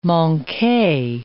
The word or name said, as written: manque